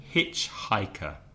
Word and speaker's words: hitchhiker: Because if you remember last week, the problem with the H sound, H, which is sometimes pronounced H by Spanish speakers. And also there are some consonants together in hitchhiker.